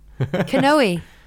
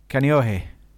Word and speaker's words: Kaneohe: Kanoe. – Kaneohe.